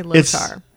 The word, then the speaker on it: Lothar: True fans say Lothar. – It's like Zoltar. It's a Lothar. Lothar. – Lothar? – It's a goddamn acronym. You can say it however you please. – I pronounce the R. – Yeah. It's a hard R. – Hard R.